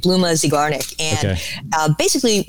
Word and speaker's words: Zeigarnik: Zeigarnik.